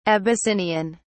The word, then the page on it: abyssinian: British English